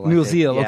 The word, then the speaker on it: Musil: Musil, okay.